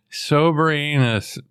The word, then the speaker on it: Soberanis: It's spelled S O B E R A N I S. – Wow. Okay, I'm glad I don't have that name. – Yes. I'm sure it's sober honest.